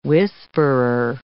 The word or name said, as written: whisperer